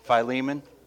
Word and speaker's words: Philemon: He writes, I say Philemon. How do you say it? Philemon. Back and forth.